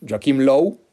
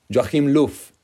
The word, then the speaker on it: Joachim Löw: Eh, a Joachim Lowe, o como le dice la, gente en general, según yo lo pronuncio como se debe, o, lo más cercano a, a, como se debe pronunciar, ¿no? Joachim Löw.